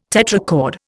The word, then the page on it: tetrachord: Indian